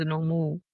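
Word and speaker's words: Noomoo: Genau, also wie Englisch ausgesprochen, O-O wie U, also Nomu.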